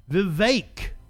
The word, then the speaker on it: Vivek: Vivek, whose name rhymes with cake, is a 9 11 truther.